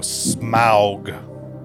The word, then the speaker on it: Smaug: I'm going to go Smaug. S-M-A-U-G. – I, too, would pronounce it Smaug.